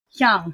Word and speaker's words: Hyung: Hyung.